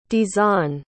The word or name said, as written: dzon